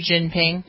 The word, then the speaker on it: Xi Jinping: The fact that there are demonstrations going on, people are being killed because of their stance, and this fellow's Ying Xing Bling, whatever his name is, who's the president. People say, that's pretty disrespectful the way you pronounce his name. – Are you kidding me? Xi Jinping? – Yeah, him.